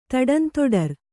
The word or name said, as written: ತಡಂತೊಡರ್